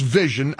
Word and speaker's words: Visyn: V-I-S-Y-N. It's pronounced Vision, a golf training app subscription.